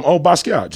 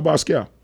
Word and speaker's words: Basquiat: Basquiat. Jean-Michel Basquiat.